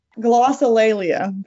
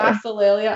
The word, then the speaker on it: glossolalia: Glossolalia, by the way. – Glossolalia. I would have said glossolalia. – Yeah, that's also how I would have said it, which is why I listened again. – Glossolalia.